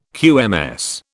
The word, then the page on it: QMS: British English